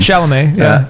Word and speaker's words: Chalamet: Chalamet, And that was unforeseen.